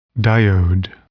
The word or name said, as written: diode